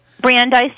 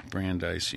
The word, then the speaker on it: Brandeis: Brandeis University, okay. – Yeah. – Brandeis University.